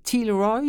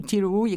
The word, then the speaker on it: Tielrooy: C'est un livre écrit par une jeune femme qui s'appelle Elise Tilroy, ou Tilroy, écoutez, ça s'écrit T-I-E-L-R-O-O-Y. Alors oui, c'est ça, hein, c'est pas évident.